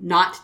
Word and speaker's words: Knot: Called Not Dice. And that's spelled K-N-O-T.